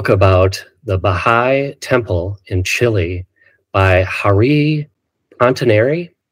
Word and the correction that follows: Pontarini: Am I saying that correctly? – Hariri and Pontarini.